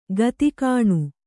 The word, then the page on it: ಗಿದ್ಗಾಣಿ: Kannada